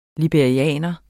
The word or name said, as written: LIBERIERNE